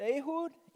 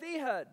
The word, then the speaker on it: Ehud: His name is pronounced Ehud in Hebrew or Ehud in English. But we're going to go with Ehud because, hey, we're English.